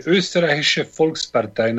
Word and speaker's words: Österreichische Volkspartei: Österreichische Volkspartei.